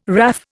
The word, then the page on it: WRAF: Indian